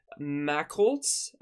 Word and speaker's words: Machholz: So this comet is called 69P Macholtz.